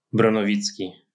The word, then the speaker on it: Bronowicki: Bronowicki.